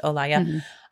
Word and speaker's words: Olaya: So that Olaya is actually how it's pronounced, Olaya, by Blanco White, the most fun song that's ever existed in the history of the world.